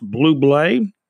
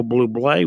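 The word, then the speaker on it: Bublé: And Michael Blue Blay, pronounced Blue Blay. That's Michael Blue Blay.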